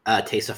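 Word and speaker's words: Taissa: Taysa? I don't know how to say her first name. – Taysa Farmiga, yeah.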